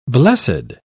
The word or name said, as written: blessed